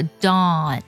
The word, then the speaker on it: dawn: 再来看第三个单词 dawn，它是黎明的意思，读作 dawn。这个单词呢英式的发音呢会把元音读作 o 的音，它的唇形呢是撅圆的，向中间聚拢的 o dawn。